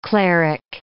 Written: cleric

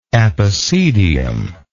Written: epicedian

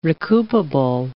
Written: recoupable

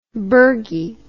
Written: bergie

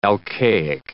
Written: alcaic

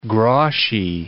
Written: groszy

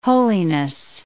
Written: holiness

